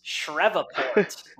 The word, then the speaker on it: Shreveport: In the Independence Bowl over in, I hope I mispronounced this, Shreveport, Louisiana.